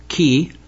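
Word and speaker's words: chi: Both the Greek letter ki, which is a the capital form of that letter is a capital X, as we would see it in English, and it's pronounced as a very guttural K.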